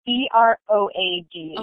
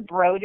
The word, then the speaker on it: Broad: pretty sure Broad is the correct pronunciation, okay. but a lot of people say Broad because it's spelled like Broad.